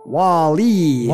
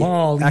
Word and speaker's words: WALL-E: I like that you said it right. You said WALL-E. – WALL-E. – Accent on the second syllable.